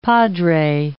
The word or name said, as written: padre